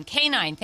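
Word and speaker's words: K'naan: Kanan Kadbi Wasam, also known as, I think I'm saying this right, Kanan? – Kanan. – Thank you.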